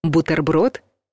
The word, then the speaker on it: бутерброд: Бутерброд — неправильный вариант. Бутерброд — правильный вариант. Возможно, вы удивитесь, но звук «т» в этом слове твердый.